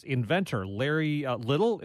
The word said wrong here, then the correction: Lytle: if I'm saying his name right. – Larry Lytle. – Lytle, yes.